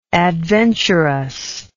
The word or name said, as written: adventurousness